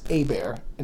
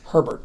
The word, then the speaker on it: Hebert: George Costanza was talking about how he enjoyed the way he pronounces Hebert instead of Herbert. – But there's or Hebert. – Hebert. – Yeah, Hebert. Yeah, I was gonna say yeah because there's no R there, but that's true.